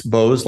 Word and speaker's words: Beaux: How would you pronounce B-E-A-U-X? Bose, like Beaux-Arts? Beaux-Arts. But isn't the museum in Paris called the Museum of Beaux-Arts? – Beaux-Arts? Yes. You don't pronounce the X.